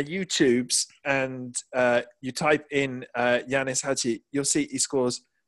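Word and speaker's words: Ianis: I think it's Yanis, isn't it? – Is that how it's pronounced? – Because I think in Romanian, an I has a U sound. So I think it's Yanis Hadji.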